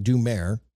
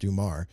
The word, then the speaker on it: Dumar: It looks like Dumair, and you spell it out, but it's actually pronounced Dumar.